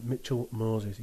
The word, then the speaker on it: Mitchell Moses: Yeah, probably Mitchell Moses, mate. Am I saying that right? Mitchell Moses, yeah. And the second part of Laurie's question is, you've got a fiver.